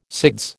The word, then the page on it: SIDS: American English